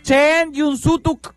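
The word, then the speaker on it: chéen jun súutuk: Se dice así, oílo, oílo, oílo. A ver, mis mayablantes. – Chen Yun Sutuk.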